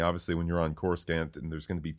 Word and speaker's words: Coruscant: Correct your pronunciation. It is Coruscant. – Coruscant.